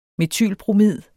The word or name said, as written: metylbromid